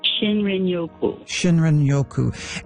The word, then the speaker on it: shinrin-yoku: I think it's called shrin-yoku. Is that how you pronounce it, the Japanese term for wood air bathing? – Shinrin-yoku.